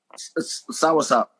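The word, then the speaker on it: soursop: This sour shot shit. Yeah. – Say it right. Sour shot. – Sour sop. This sour sop.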